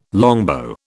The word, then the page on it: longbow: British English